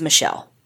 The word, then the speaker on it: Mitchelle: Michelle is spelled M-I-T-C-H-E-L-L-E. And I've heard it pronounced both ways, but from what I can gather from the court recordings, it's Michelle. So that's the pronunciation we'll go with.